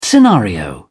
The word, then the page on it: scenario: British English